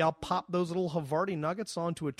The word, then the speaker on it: Havarti: Yeah, and your little insert in every podcast you do, you mention Harskavarni cheese. – Which is actually pronounced Havarti, but you have some Havarni. Sort of black dot on your brain that prevents you from fucking saying it right.